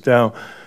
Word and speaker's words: Tao: So when I say Tao, hopefully you can hear the T, which in the romanized version of the Chinese is spelled T, but it's pronounced a little bit more like D or DH.